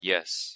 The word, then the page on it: yes: English